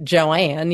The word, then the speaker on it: Joan: And my mother was named after Joan Crawford, except down south they pronounce it Joanne, you know.